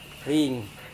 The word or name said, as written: rin